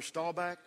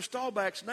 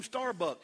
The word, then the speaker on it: Staubach: He can't even pronounce Roger Staubach's name right. He calls him Starbuck.